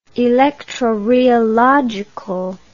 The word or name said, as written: electrorheological